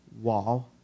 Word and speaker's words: vav: vav adversative. Vav is, in fact, the sixth letter in the Hebrew alphabet. Some people pronounce it waw, like W-A-W, waw.